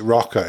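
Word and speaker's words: ROKR: Uh, I, I, pronounce it Rocker. It's R O K R.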